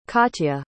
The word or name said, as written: katya